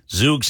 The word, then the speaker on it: Zoox: Is it Zoox? Z-O-O-X?